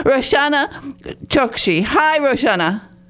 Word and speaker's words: Chokshi: Chokshi.